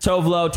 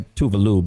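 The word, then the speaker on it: Tove Lo: Then you got Tovlo. Technically, she goes by Tuvalu, but no one pronounces it right.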